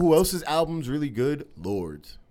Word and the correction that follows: Lorde: Lords. – Or is that how you say her name? Lord? – Lord, yeah. – Lordy. – Yeah. – L-O-R-D-E.